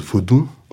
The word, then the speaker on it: vaudou: Donc ça se prononce vaudou donc avec un, final nasalisé, Peu de gens connaissent la signification, s'intéressent à la signification du mot.